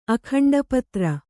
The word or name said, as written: ಅಖಂಡಪತ್ರ